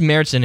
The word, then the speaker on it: Märzen: It's Märzen in German, but I think we call it Märzen, which makes it very rock and roll. – It makes it the high A. Nice. Like that long A, Mär. Because März is March in German, so Märzen would be the beer that was brewed in March.